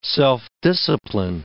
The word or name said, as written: self-discipline